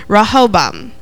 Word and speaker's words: Rehoboam: Now, this name's a little hard to pronounce, but how you pronounce it is Rehoboam.